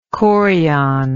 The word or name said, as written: chorial